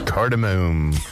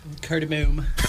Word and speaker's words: cardamom: Cardamom. – Oh yeah. – Gotta have that. How do you say it? – Cardamom. – Okay, just want to double check. Make sure the pronunciation is correct.